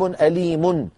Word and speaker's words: أليم: عذاب أليم، نحذف التنوين ونحذف الحركة ونخب السكون ثم نضم الشفتين من غير صوت.